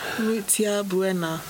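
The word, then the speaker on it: Mutya Buena: Mutia Buena.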